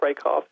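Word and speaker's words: Rakove: I know I read some of your footnotes, and Jack Rakoff, is that the way you say his name? – Jack Rakoff, yes.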